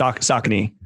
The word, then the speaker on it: Saucony: I always forget how it's pronounced. – Saucony. – Yeah.